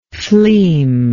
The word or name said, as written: fleam